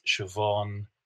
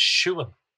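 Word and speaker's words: Siobhán: They have BH, MH, they sound like V. But if you look at the spelling of those words, they are broad BH, broad MH. So I believe those are Munster pronunciations, Siobhan, Niamh. And in Donegal Irish, the name Siobhan is Siúan.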